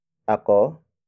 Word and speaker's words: ako: For example, nagtanong ako kay Eva. Nagtanong ako kay Eva. Nagtanong ako kay Eva. It means, I asked a question to Eva. Word for word, it's nagtanong ako kay Eva.